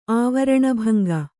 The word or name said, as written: ಆವರಣಭಂಗ